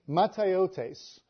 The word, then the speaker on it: mataiotes: The word is mataiotes. I'll spell that M-A-T-A-I-O-T-E-S. Mataiotes. And that is the same word that is used in the Greek Septuagint version of Ecclesiastes.